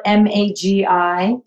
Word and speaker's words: Magi: Uh, my my name is Magi Pierce. I spell it M A G I P I E R C E.